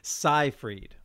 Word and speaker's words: Seyfried: Which one is it? – Seyfried, like sci-fi.